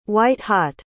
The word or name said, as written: white-hot